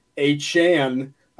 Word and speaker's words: Achane: It's Tyreek Hill, Devon Achane, saying that correctly.